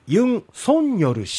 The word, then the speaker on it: ユン・ソンニョル: め走行試験始まるまずは韓国大統領にユン・ソンニョル氏が就任しましたえー、昨日から報道では、まあ、いろんなメディアでは大統領の名前、ユン・ソンニョル氏